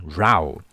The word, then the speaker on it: row: 那当念吵架的时候，它是念成 row。